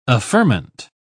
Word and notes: affirmant: British English

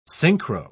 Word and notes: synchro-: British English

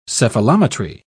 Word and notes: cephalometry: British English

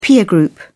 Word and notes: peer group: British English

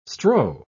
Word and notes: strow: British English

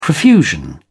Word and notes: profusion: British English

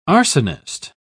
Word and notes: arsonist: British English

